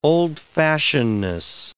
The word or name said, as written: old-fashionedness